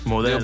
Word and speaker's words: Modelo: Modelo.